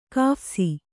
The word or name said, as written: ಕಾಫ್ಸಿ